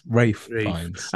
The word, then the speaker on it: Ralph Fiennes: We pronounce it Ralph because we're normal people. – Okay. – But when you're in the land of the posh people, it's Ralph Fiennes.